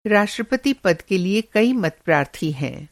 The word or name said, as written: मतप्रार्थी